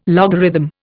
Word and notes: logarithm: Indian

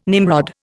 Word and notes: Nimrod: Indian

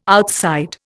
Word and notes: outside: Indian

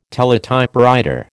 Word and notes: teletypewriter: American English

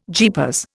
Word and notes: jeepers: Indian

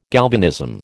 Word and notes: galvanism: American English

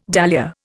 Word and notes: dahlia: Indian

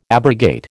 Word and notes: abrogate: American English

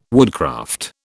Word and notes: woodcraft: British English

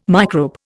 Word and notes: microbe: Indian

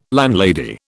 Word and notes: landlady: British English

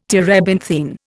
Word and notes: terebinthine: Indian

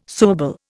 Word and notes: suable: Indian

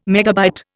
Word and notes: megabyte: Indian

